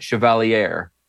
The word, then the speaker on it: Chevalier: Lost the primary to Mamdani-backed Daria Lisa Avila Chevalier, I believe is the actual pronunciation, even though I want to say Chevalier. – Chevalier. Is it Chevalier? It's Chevalier? Like the Chevy Cavalier mashed into one word.